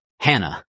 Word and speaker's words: Hannah: So actually in Greek, it's pronounced Hannah. So there actually is that H sound there.